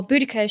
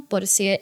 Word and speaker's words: Boudica: In our London version, in the Tilted London, Boadicea, or Boudica, she's more commonly known, Boadicea is the Victorian pronunciation.